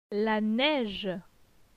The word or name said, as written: La neige